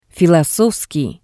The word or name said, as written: filosofskii